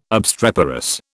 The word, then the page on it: obstreperous: British English